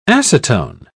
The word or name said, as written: acetone